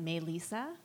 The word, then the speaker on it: Melissa: And my Filipino nickname is Misa, which is short for the way Filipinos pronounce Melissa. They pronounce it Melisa.